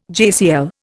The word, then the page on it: JCL: Indian